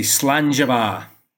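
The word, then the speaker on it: slàinte mhath: And how we say in Scotland, we say slangeva. – Slangeva.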